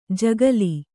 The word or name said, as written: ಜಗುಲಿ